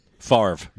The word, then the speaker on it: Favre: Favre.